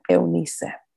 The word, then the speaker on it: Eunice: Hello, my name is Hilda Eunice Burgos. In Spanish, my first and middle names are pronounced Hilda Eunice.